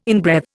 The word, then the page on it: inbreathe: Indian